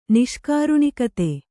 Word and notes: ನಿಷ್ಕಾರುಣಿಕತೆ: Kannada

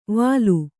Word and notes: ವಾಲು: Kannada

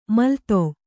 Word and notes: ಮೆಲ್ಲೆದೆ: mellede

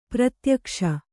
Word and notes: ಪ್ರತ್ಯಕ್ಷ: pratyakṣa

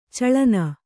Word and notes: ಚಲ್ಲೆಣ್ಣೆ: Kannada

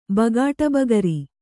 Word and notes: ಬಗಾಟಬಗರಿ: bagāṭa bagari

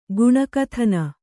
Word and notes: ಗುಣಕಥನ: Kannada

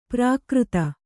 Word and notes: ಪ್ರಾಕೃತ: prākřta

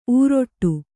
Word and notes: ಊರೊಟ್ಟು: ūroṭṭu

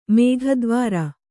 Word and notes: ಮೇಘದ್ವಾರ: mēgha dvāra

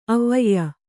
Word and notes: ಅವ್ವಯ್ಯ: avvayya

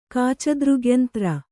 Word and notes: ಕಾಚದೃಗ್ಯಂತ್ರ: kāca dřgyantra; kannada

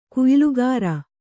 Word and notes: ಕುಯ್ಲುಗಾರ: kuylugāra

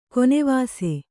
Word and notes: ಗೋನಿವಾಸಿ: gōnivāsi

